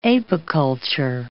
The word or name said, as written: apiculture